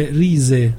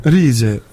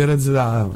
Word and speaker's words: RZA: quello del Budan Clan la pronuncia esatta Rise Rise eh, Scusa, okay.